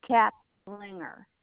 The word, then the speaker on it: Caplinger: Kaplinger, C-A-P-L-I-N-G-E-R. – All right. I'm sorry, and how do you pronounce that again? – Kaplinger.